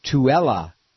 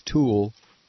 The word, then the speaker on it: Tooele: It's Tooele. Tooele, Utah. Tooele. Thanks. I didn't think it was Tool.